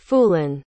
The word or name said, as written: phoolan